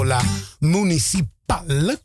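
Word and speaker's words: La Municipàl: Una canzone che noi abbiamo presentato la settimana scorsa, anche perché è una canzone di recente, da parte del gruppo La Municipal, con l'accento sulla A finale.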